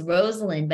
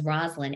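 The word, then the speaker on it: Rosalynn: And the fact that people still mispronounce her name really bothers her. It's Rosalind, but everyone says Rosalind, including me when I first met her.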